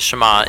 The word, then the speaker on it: Shema: Now the first word of the Shema is hear, or listen, which in Hebrew is pronounced Shema.